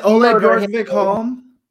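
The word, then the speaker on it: Ole Bjørkvik-Holm: Is it Ole Bjorkvik Holm? – It is. Thank you.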